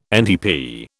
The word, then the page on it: NTP: British English